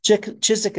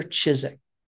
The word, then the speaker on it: Ciszek: And I encourage you to pick up this book, get yourself a copy of the book, He Leadeth Me by Walter Chizik. Chizik is spelled C-I-S-Z-E-K, Walter Chizik.